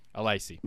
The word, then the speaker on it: Alesi: Alesi.